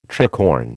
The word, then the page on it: tricorn: American English